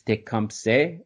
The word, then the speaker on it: Tecumseh: Properly pronounced, to the best of my knowledge, in the indigenous language of Shawnee, it's Tecumseh. Hopefully I did that okay. I'll probably revert back to my whatever Anglo pronunciation, since it's just more comfortable coming off of my tongue.